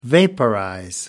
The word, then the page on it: vaporize: American English